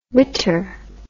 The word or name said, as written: witter